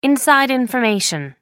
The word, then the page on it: inside information: American English